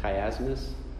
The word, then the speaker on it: chiasmus: Did I say it wrong? Is it chiasmus? Okay. – Chiasmus. – That's what I looked it up. I listened to it yesterday several times. Because I always thought it was CH more, but it's a K.